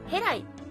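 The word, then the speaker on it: 戸来: で、このキリストの墓のある地区というのが、ヘライ、さっきのヘですね、ヘに来ると書いてヘライというみたいなんですが、これはヘブライからやってきたんだ、というようなお話も残っています。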